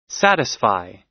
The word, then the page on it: satisfy: American English